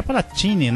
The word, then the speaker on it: Palatini: Ouvimos O Último Romântico na voz do Agnaldo Timóteo, de Palacini, acho que é Palatini, né, que pronuncia italiano, Palatini e Donaggio.